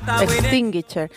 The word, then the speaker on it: extinguisher: ¿Cómo se pronuncia? – Extinguisher. ¿Pero y qué otra palabra es así en Estados Unidos? Sándwich. – Sandwich. – Sandwich. – Extinguisher. – Sándwich. Fire extinguisher.